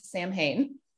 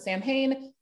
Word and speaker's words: Samhain: It is a Gaelic word. We think it's pronounced Samhain or Samhain, but nobody can be 100% sure.